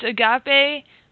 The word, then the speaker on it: agape: It's spelled A-G-A-P-E, and it's agape.